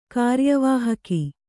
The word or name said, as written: ಕಾರ್ಯವಾಹಕ